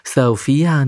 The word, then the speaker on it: Sophia: Today my friend Sophia is here。今天呢，我们邀请到了乐活王子的朋友，他的名字叫 Sophia。那这个名字呢，跟我们一般所听到的 Sophie 啊，S O P H I E 发音有点不一样。 Sophia 呢，可能是源自于拉丁文或者是西班牙文啊，它的重音是放在第二个音节哦。